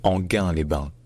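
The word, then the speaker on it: Enghien-les-Bains: Parce que ça ne se dit pas Angien-les-Bains, ou Anguien-les-Bains, ou même Engin-les-Bains, mais Enguin-les-Bains. Enguin-les-Bains.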